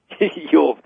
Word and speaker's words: Youells: Ewells. And that's spelled Y-O-U-E-L-L-S.